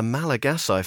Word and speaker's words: Malagasy: And Madagascar's shock 2-0 win against Nigeria means that the Malagasy, for that is how you say it, thank you, Manny, playing in their first ever AFCON are through to the last 16.